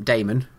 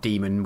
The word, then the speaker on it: Damon: Getting a gaming group together with Following on from that, it's quite closely related question from Damon. And just to clarify, that's D-A-M-O-N, not Demon with an A and mispronounced Damon.